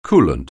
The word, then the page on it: coolant: anglais